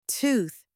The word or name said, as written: tooth